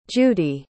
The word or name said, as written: judie